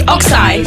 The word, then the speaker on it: Oxeye: Am I saying it correctly? Oxeye. Oxeye. – Yeah. As in like a bovine animal's eye.